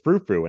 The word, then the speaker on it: Frou Frou: which I embarrassingly walked down and pronounced Frau Frau for about 10 years of my life until someone said, I think it's Frou Frou. And I said, What? I've been saying Frau Frau for 10 years.